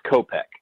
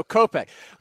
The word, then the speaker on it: Kopech: So, is it Kopech or is it Kopech? Because I've seen so many different pronunciations, I want to get the clear one for first. – It's Kopech. – Okay, so Kopech.